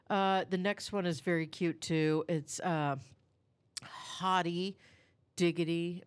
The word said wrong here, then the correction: Haute: Am I saying that right? – Hot Diggity Dog. Oh, I see. H-A-U-T-E.